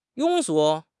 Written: 庸俗

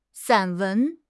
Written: 散文

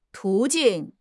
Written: 途径

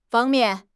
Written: fang1 mian4

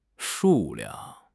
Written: shu4 liang4

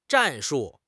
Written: 战术

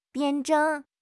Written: 辩证

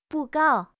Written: 布告

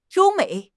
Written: you1 mei3

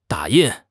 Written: da3 yin4

